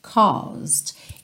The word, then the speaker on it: caused: Caused. Essa primeira parte da palavra, essa primeira sílaba, é mais é, comprida, caused, caused, ok? O E não é pronunciado, então você não vai falar caused, caused, caused.